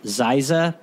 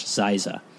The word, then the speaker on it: Dzieza: and it was Josh Ziza, but his name is spelled with a D, so D-Z-I-E-Z-A, Josh Ziza.